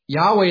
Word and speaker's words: Yahweh: Yahweh is probably the closest we will come to pronouncing it correctly, as it is designated with the Hebrew consonants YHWH.